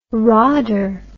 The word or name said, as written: rodder